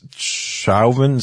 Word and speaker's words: Chauvin: They were either there or they, you know, they may have some information about the character of Calvin, of Coven. How do you say his last name? – It's Coven? It's Chauvin, C-H-A-U-V-I-N, right.